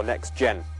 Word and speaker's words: NXTGen: I have been posting something called hashtag NXTGen, or Next Gen, as it's pronounced.